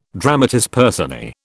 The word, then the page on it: dramatis personae: British English